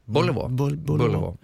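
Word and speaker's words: Béliveau: Och sen 10, hur uttalar du det efternamnet? – Bullivå. – Ja.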